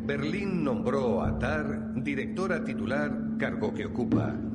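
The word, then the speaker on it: Tár: Tar, T-A-R, con acento en la A, que también hay que tenerlo en cuenta.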